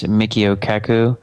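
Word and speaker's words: Michio Kaku: Mickey, I always pronounce his name wrong. It's Mikio Kaku. – Okay, Mikio Kaku.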